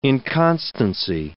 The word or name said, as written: inconstancy